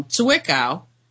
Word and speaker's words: Zwickau: You know, I've talked about the so-called Zwickau, I keep pronouncing it wrong, Zwickau,